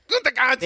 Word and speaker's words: Gutekunst: That's a good pronunciation. – Gutekunst.